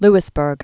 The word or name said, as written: Louisbourg